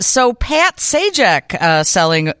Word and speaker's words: Sajdak: He was known as Patrick Leonard Sajdak, pronounced Sajak, but there was a D in it.